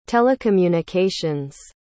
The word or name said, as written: telecommunications